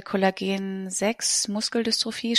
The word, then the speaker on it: Kollagen 6 Muskeldystrophie: Mira hat die Diagnose Kollagen 6 Muskeldystrophie. Spricht man das so aus? – Ja, das ist richtig.